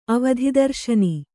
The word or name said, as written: ಅವಧಿದರ್ಶನಿ